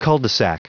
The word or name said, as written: cul-de-sac